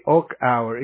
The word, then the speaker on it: Achauer: That's the name of it. Nancy Day Okauer. It's spelled A-C-H-A-U-E-R.